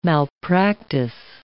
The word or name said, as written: malpractice